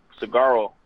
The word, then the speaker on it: Saguaro: We play a 0-0 game against Saguaro. I think the G is silent there. – Yep, you got it right.